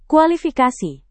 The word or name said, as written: kualifikasi